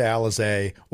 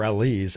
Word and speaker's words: Alizé: Nixon Newell, Miranda Alizé, or Elise, as it's pronounced correctly.